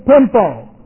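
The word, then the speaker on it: pempo: The other is pimpo. P-E-M-P, long O.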